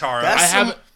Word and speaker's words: Katara: Anna would kill me if I didn't say Kamara and Zuko from Last Airbender. – Wow. You said her name wrong. – That's really... – I haven't finished... – It's Katara.